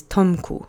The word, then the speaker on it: Tomku: The accent is on the first syllable of the word. Let's say it together three times. Volnoch. Volnoch. Volnoch. Good. The next word is Tomku, which is the object form of the diminutive of the name Thomas.